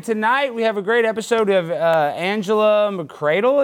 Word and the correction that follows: McArdle: Is that how I think I say her name? – McCardle.